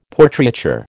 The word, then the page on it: Portraiture: American English